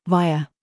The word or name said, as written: PRICE